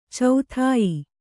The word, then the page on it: ಚೋದ್ಯ: cōdya